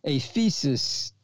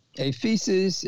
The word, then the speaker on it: aphesis: This word remission comes from the Greek word aphesis, A feces is spelled in the English A-P-H-E-S-I-S, a feces, A-P-H-E-S-I-S, a feces.